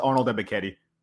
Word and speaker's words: Ebiketie: Yeah, I was hoping you would say it, so I didn't have to, but Ebiketite. – It's just Arnold Ebiketie. – Ebiketie, that's easy.